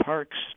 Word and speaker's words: Parkes: and the Parkes Telescope, Parkes spelled with an ES at the end, which is the largest radio telescope in the Southern Hemisphere.